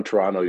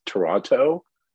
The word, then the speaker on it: Toronto: If you're from Toronto, you don't pronounce the T in the middle. So if you hear someone say it's Toronto, they're not from Toronto.